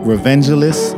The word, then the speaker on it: Revengelist: Uh, yeah, quick shout out to a new podcast soon to uh, be launching Revengelist Podcast.